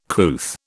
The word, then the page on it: couth: British English